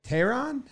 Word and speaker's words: Teheran: They got Julio Teheran.